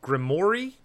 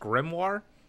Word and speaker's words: grimoire: Especially when Alan calls it a grimoire, when they're actually, it's pronounced grimoire.